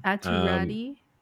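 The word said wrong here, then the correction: Räty: It's apparently pronounced. – It's actually pronounced Ratu. – Yeah, it's pronounced Atu Ratu. – Oh, sorry. – I know. – Sorry, Ratu. It's weird. Sorry, it's not weird. It's a different language.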